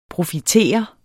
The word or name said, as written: PROFITERET